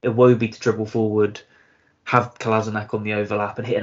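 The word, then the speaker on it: Kolašinac: That's Sayad Kolasinac for the next time you guys want to pronounce it.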